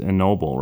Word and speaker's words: Ennobble: and forgive me if I mispronounce it, it's Ennoble, right? – That's correct.